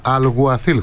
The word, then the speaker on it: Αλγουαθίλ: Δηλαδή και ο καινούργιος μου προπονητής φαίνεται ότι πρέπει να προφέρεται Αλγουαθίλ, χωρίς το ΚΑΠΑ, αλλά τέλος πάντων.